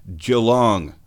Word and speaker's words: Geelong: It's not Geelong, because it's not tea. You know, you see it, it says... It looks like oolong tea, but it's Geelong. But it's not pronounced Geelong. That word is Geelong.